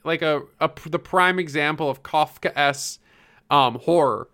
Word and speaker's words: Kafkaesque: Hold on, I looked it up earlier so I could say it. Kafkaesque. – Kafkaesque. – Yeah, Kafkaesque.